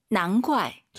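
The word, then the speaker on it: 难怪: 자, 발음하실 때 선생님, 이 난怪, 우리가 이제 GUAI 사성이니까 怪, 쌍격으로 많이 발음해요. 난怪가 예뻐요? 난怪가 예뻐요? – 다 예뻐요. – 다 예뻐요. 근데 쌍기역으로 난과이 이렇게 발음하는 중국인도 있죠. 근데 우리 왕 선생님은 난과이 기역 느낌으로 발음을 했다는 걸 참고하시면 좋을 것 같습니다.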